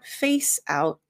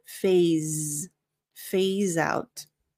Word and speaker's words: phase out: So it's not face out, but phase out.